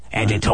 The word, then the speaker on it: Editor: Because you have to pronounce the Tor in Doctor Who. It has to be Doctor, Editor.